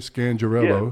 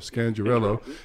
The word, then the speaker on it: Scangarello: Is that how you say it? Scangarello. – Yeah. – Scangarello.